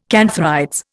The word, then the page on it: cantharides: Indian